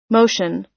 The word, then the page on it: motion: American English